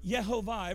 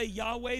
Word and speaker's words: Yehovah: Some people say Yahweh, that's not how you say it. That's actually breaking it all the way down. That, but it's it's Yehovah. That's how you would pronounce YHWH.